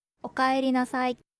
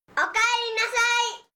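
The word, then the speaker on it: اوکایری ناسای (おかえりなさい): اوکایری ناسای. – اوکایری ناسای. – اوکایری ناسای.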